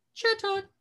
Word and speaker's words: Chatot: Chatot. I'm really excited for him. He's cute. – Chatot.